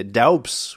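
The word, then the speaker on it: Doubs: Alors, j'ai entendu, j'ai regardé le replay US où il disait Daubs, donc je dis tout le temps d'où, parce que ça s'écrit comme le département, mais c'est Daubs apparemment.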